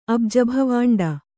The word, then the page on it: ಅಬುಜಭವಾಂಡ: Kannada